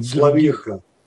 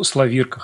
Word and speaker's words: Славирка: Когда появился герой Славерка, думал диалоги... – Славирка. – Ну, Славирка, хорошо. Здесь просто не ставится нигде ударение.